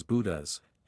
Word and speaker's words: Boudeuse: The Bodieu sofa, pronounced as Boudas, and translated to sulky in French, would be ideal for you.